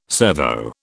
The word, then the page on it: servo: British English